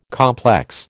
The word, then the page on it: complex: American English